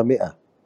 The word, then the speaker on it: مئة: أو كلمة ماء أنا بلفظها مئة مع إنها تكتب آه، ميم مكسورة ألف آه، همزة على كرسي تاء مربوطة ولا ألفظ الألف